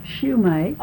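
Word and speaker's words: Sumac: How do you say it? – Schumach.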